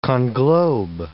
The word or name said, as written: conglobe